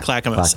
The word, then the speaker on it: Clackamas: Yeah, Clackamas. Clackamas.